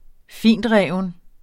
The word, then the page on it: fintreven: -ˌʁεˀvən